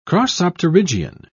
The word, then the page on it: crossopterygian: British English